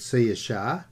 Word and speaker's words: Saoirse: Now, I think I've mentioned other times, I am 25% Irish, and maybe that's why I like this group called Sia Shah. And as I've said once before, S-A-O-I-R-S-E. I'm glad someone told me how to pronounce it, because I would never have worked it out.